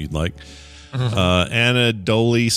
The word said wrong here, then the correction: Andouille: sauce. Am I saying that right? – Andouille. – Andouille. – Like Andouille sausage, like it's in your jambalaya.